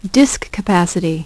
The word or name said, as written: disk capacity